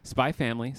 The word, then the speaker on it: Spy x Family: Spy Family, Silent X.